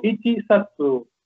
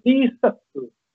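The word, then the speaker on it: Isatsu (一冊): No se, ichi, no se dice, digo, Ichi-satsu. Tampoco se dice Hachi, como 8. Hachi-satsu tampoco se dice. Se dice Isatsu.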